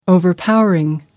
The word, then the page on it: overpowering: American English